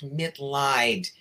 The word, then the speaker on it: Mitleid: The last name is pronounced Mitleid, and that's because it's a chosen last name. It's German.